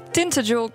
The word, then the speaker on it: Tintagel: Daar staat namelijk Tintagel Castle, zo spreek je dat uit.